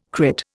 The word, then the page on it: crit: Indian